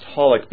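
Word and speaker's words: xxxHolic: No, it's actually just pronounced Holic because the triple X part at the beginning isn't like triple X, like, you know, flashing neon sign triple X, but it means a variable.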